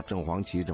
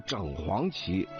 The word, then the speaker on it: 正黄旗: 因为北京啊好几个地名啊，就是比如说正黄旗啊、正蓝旗啊，这地名是这个，所以呢很多人也就念了啊，正黄旗、正白旗。实际上它正确的读音是整啊，是整黄旗、整白旗啊、整红旗、整蓝旗啊。老舍先生有一本小说的名字叫整红旗下啊，很多人都给读成了正红旗下了啊，实际上是错的啊。